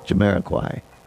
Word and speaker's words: Jamiroquai: How do you say it? Jamiroquai. I got it right now.